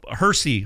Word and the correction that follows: Hershey: Hershey, Pennsylvania.